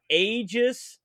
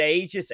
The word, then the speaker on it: Aegis: So Ports of Call, released in 87 on a disc, published by Aegis. I believe I've always thought that's the way you pronounce that, AEGIS.